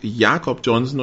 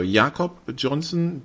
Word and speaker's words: Jakob Johnson: Also der Herr Johnson heißt Jakob Johnson und legt Wert darauf, dass es übrigens deutsch ausgesprochen wird.